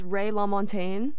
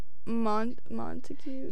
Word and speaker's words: LaMontagne: This is Ray LaMontagne, inspiration from my father, so. – I was going to pronounce that, like, Montague.